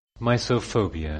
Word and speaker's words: misophobia: Misophobia. – Misophobia. Sorry, I said miso. It's misophobia. One more time.